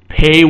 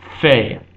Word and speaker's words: Pe: Pay without the dagesh, without the dot in the middle, is pronounced fey. So it is both pay and fey in original Hebrew, and also in modern Hebrew as well.